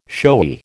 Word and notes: showy: American English

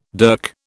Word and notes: dirk: British English

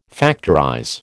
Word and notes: factorize: American English

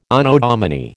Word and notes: Anno Domini: American English

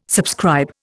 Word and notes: subscribe: Indian

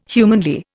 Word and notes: humanly: Indian

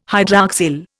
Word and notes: hydroxyl: Indian

